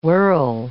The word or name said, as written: whirl